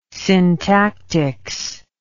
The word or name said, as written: syntactics